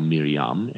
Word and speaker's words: Miriam: And from there, kind of using the manger as a muse, we go back to Nazareth and Mary and Joseph, whom we call Miriam and Yosef, using the actual Hebrew pronunciations of their names,